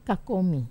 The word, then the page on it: kakomi: wamesa